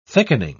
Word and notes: thickening: British English

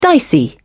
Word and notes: dicey: British English

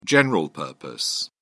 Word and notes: general-purpose: British English

